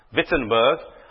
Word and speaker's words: Wittenberg: Or I'm not sure if you're supposed to pronounce it with a V as Wittenberg. You are? Wittenberg.